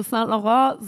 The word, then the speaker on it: Saint Laurent: Naja, ähm, also eigentlich, also Saint Laurent, so sprechen wir, also das europäische äh, Chapter das ja auch aus.